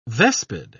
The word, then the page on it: vespid: British English